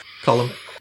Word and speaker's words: Colm: Oh, Colm. Colm. – I don't know how it's pronounced. – Either. – It's not Colin.